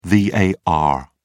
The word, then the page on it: var: British English